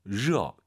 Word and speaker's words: 热: 热. – 이제 청취자분들 꽤 많은 분들이 러 발음이 잘 안 되는 분들도 있어요. 러 이렇게 해요. 러. 그럼 오히려 L 발음에 가깝고요. 러.